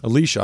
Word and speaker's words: Alicia: Alicia.